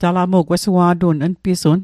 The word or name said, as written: npisun